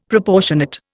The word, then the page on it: Proportionate: Indian